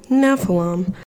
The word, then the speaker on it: Nephilim: Okay, yep, I was wrong. Nephilim.